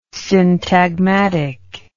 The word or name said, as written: syntagmatic